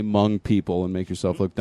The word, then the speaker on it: Hmong: But it's pronounced Hmong. – Okay, yeah. There's a silent H there.